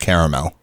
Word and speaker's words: caramel: You know, you hear caramel a lot. Like they just they forget the second A, but it is technically pronounced caramel.